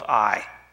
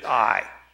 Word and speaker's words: Ai: Then in Joshua chapter 7, there's a great defeat at a city down the road called Ai. Some would call it A, because it's spelled A-I.